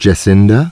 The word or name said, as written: Jacinda